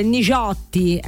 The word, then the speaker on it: Nigiotti: Enrico Nigiotti, ecco, questa è la pronuncia livornese giusta, Nigiotti, ecco, con Tu sei per me.